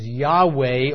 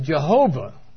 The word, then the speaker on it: Yahweh: And we think probably the most likely pronunciation is Yahweh or Yahweh. Of course, many people pronounce it as Jehovah, which has the same consonants, but just different vowels and different emphasis on the syllables.